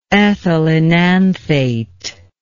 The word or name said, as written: ethylenanthate